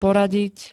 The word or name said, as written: poradiť